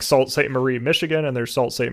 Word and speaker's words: Sault Ste. Marie: I am editing this podcast right now, and I have since realized that it is actually pronounced Sault Ste. Marie, so I'm going to mispronounce it throughout the entire podcast.